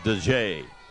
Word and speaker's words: Dajhae: It's spelled D-A-J-H-A-E and pronounced DeJay.